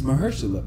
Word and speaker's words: Mahershala: No, my coworker said, it's Mahershala.